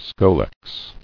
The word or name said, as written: Scolex